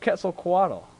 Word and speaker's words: Quetzalcoatl: I think 23 was the return of Quetzalcoatl.